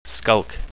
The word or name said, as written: skulk